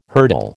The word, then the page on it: hurdle: American English